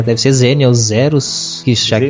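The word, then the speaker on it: Xenial Xerus: Vamos ver o que, que ele diz. Zinio Zeros.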